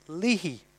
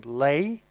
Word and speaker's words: Leahy: Leahy. The Americans pronounce that surname Leigh, but Martin Leahy is a prominent fella in the music biz back in Ireland.